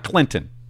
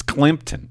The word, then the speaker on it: Climton: I'm gonna say it's Climpton. Let me spell it. It's not Clinton. It's Climpton. C-L-I-M, not N. M as in Mike. C-L-I-M-T-O-N 11-1-1.